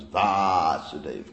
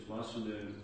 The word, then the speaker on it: Vasudev: So Krishna's with a line. – Long A. – Okay. – Krishna is Vas. Krishna outside of Vrindavan is Vasudev Krishna. – So it's Vasudev Vas. Vasudev. Vasudev. Can you hear the difference? – It sounds very similar.